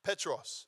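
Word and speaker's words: Petros (Πέτρος): Now that word Peter, if you look at the original language, the Koine Greek language, or the old school language, the word Peter is Petros, okay? That's the English equivalent or how you would pronounce it in Koine Greek, Petros.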